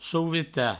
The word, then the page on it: sovetar: provençal; souveta